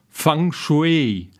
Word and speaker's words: Feng Shui: Ab 600 vor Christus, zur Zeit der 100 Schulen, in dieser Zeit wirkte übrigens auch Konfuzius, da änderte man den Namen auf Feng Shui, was eigentlich richtig ausgesprochen Fang Shui heißt und zwar Wind und Wasser.